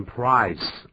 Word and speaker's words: prize: The prize 要变成 prize，啊，这是小地方的发音要记住。 – 对，这个 z 是念作 z 而不是 s 的音。